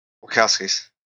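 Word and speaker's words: Wachowski: Of course Wachowskis. It was. – I never know how to pronounce it. – Wachowskis.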